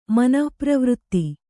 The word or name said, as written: ಮನಃಪ್ರವೃತ್ತಿ